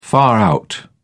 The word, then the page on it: far-out: British English